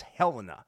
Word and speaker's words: Helena: He was the CO of the USS Helena. And I know how to say that right because it's the Helena, not the Helena, because Helena is the capital of Montana, and I'm from Montana.